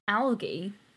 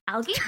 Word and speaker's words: algae: I was doing a video about the nudibranchs that take in the chloroplasts and like photosynthesize and stuff like that, and I'd said the word algae, which obviously is pronounced differently in different places. – I remember this. – Yeah. And just like the number of comments being like, algae, you're pronouncing it algae, it's algae, come on, like it's algae.